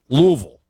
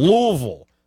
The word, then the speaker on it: Louisville: Louisville. Almost, it almost is one syllable, Louisville, but it's not Louisville.